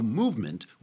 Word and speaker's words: MVMT: So Movement, which is spelled for some reason MVMT, MVMT, all the vowels and other letters are just left out, but it's MVMT.